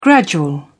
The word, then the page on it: gradual: British English